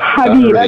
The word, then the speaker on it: Khabib: Habib. The K is silent.